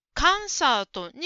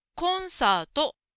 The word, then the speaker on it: コンサート: それとコンサートという言葉ですが、カンサートに聞こえました。コンサートはコが大事ですから、しっかり発音しましょう。コンサート、コンサートです。